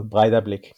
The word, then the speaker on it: Breiðablik: But I will, Breiðablik.